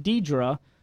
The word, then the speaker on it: Deidra: My name is pronounced Deidre, but I usually get everything but that because I've given up and I love to answer more because her name is spelled D-E-I-D-R-A.